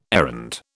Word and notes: errand: British English

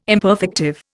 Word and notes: imperfective: Indian

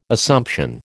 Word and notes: Assumption: American English

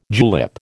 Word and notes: julep: American English

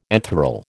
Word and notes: enthral: American English

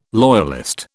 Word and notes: loyalist: British English

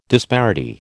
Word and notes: disparity: American English

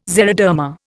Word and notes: xeroderma: Indian

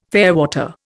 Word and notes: fairwater: Indian

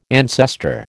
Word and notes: ancestor: American English

